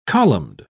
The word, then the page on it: columned: British English